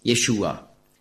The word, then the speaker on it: Yeshua: Yeshua war ein Problem für die Griechen. Denn im griechischen Alphabet gibt es keinen Sch-Laut, sondern es gibt nur das Sigma, S.